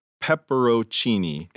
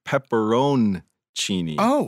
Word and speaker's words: pepperoncini: But today I heard you say pepperoncini instead of the correct pepperoncini, where the N is pronounced.